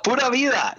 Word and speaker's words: Pura Vida: Am I saying that right? Rally team. – Pura Vida. Yes.